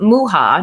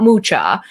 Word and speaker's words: Mucha: Contrary to popular belief, it is pronounced Mucha, not Mucha, was one of the big artists that she drew inspiration from.